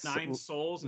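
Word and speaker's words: Nine Sols: That's Nine Souls. And Nine Souls. Spelled like SOLS.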